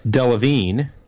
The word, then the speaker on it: Delevingne: I'm looking at the pronunciation on Wikipedia. Kara Delevingne.